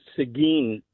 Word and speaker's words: Seguin: The little town is actually pronounced Seguin,